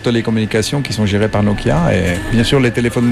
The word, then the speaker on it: Nokia: Mais il n'y a pas, faut pas accentuer la prononciation de chaque lettre. – Non. – Nokia.